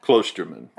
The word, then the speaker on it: Klosterman: Don't you say his name differently than that? – Klosterman. – Okay. – Yes.